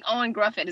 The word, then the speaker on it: Ioan Gruffudd: I like I like Ioan Gruffudd.